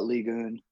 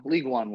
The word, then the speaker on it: Ligue 1: If you want to say it really like really pretentiously, you can say it like the French say it and call it Ligue 1. But, you know, League One works.